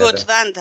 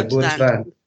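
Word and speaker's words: گتوند: امیدوارم اسمش رو درست تلفظ کنم توی این چیزایی که به صدا تحقیق کردم. – گودوند هست اسمش. – بله گودوند.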